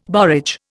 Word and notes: borage: Indian

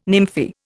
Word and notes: nymphae: Indian

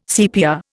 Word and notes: CPR: Indian

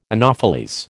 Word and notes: anopheles: American English

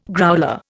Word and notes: growler: Indian

